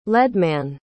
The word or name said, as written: leadman